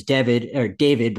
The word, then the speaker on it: Devid: His name is David, but it's spelled D-E-V-I-D.